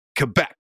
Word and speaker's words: Quebec: Are you pronouncing it Quebec? Yeah, Quebec. He didn't say, well, why would I entertain a Canadian football player?